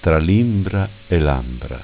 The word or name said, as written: Ambra Imbra